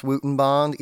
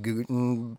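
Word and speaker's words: Goudenband: Leafman's Wootenbond, and it is pronounced Wootenbond, even though it looks like Gutenbond or something like that.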